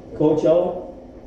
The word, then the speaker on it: Coachella: Interesting thing, we got to Coachella Valley, am I saying that right? – Coachella, and we did a tour of their water.